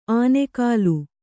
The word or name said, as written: ಆನೆಕಾಲು